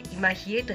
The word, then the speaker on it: 今比叡: まず 11 月の 14 日土曜日には今比叡神宮でお火焚き祭が行われます。午後3 時からです。境内では湯立神事も見られます。今比叡神宮、新日吉と書いて今比叡と読みます。